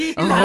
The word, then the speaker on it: Vokuhila: Vokuhila.